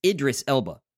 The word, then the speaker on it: Idris Elba: Now, I also see that you've marked here in our show notes that it's not the ego, but the id. It's Idris Elba.